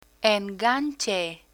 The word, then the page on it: enganche: Spanish